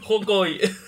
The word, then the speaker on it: Hawkeye: Ojo la con en inglés. Hawkeye.